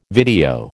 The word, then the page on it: video: American English